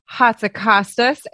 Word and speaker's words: Hatzikostas: Hatzikostas.